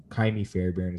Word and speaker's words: Kaimi Fairbairn: Kaimi, Kaimi Fairbairn is how you say his name.